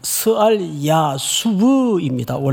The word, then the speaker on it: 스알 야숩: 스알 야수브입니다. 원래 발음은.